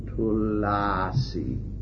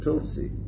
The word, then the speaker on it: tulsi: That's another one that we mispronounce. I have never ever seen it written tulasi. It's tulsi. Anyway. In tasting the tulasi